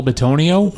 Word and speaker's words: Bitonio: Joel Benito. Is that right? Did I butcher that one, Jonas? You know who that is, right? Joel Bitonio? Yeah, I butchered that one.